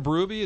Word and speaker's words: Berube: Instead of Berube, it's Berube.